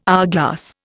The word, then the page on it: hourglass: Indian